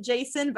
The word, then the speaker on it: Jacin: Next is Winter and Jason. – Whoa, okay. It's spelled J-A-C-I-N. Never would have guessed you pronounce it Jason, but I've learned that today.